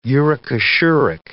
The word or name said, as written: uricosuric